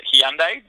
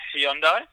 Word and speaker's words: Hyundai: which over here they pronounce it Hyundai, but I guess the Koreans pronounce it Hyundai.